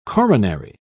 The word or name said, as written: coronary